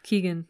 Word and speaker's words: Keoghan: Keoghan, that's how you say it.